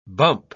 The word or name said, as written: bump